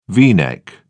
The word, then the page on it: v neck: British English